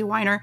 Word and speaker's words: Weiner: Uh, that's how she pronounces her name. I, I, hope I'm getting that correct. But yeah, I think uh, she said specifically Weiner.